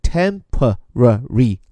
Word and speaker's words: temporarily: 注意这个词的发音很有意思。如果按照美国人的发法，一般各个音节发的比较完整，叫做 temporarily。